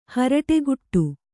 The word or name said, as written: ಹುರುಡುಕಟ್ಟು